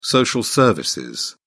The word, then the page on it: social service: British English